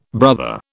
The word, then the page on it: brother: British English